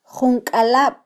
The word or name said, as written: junkalab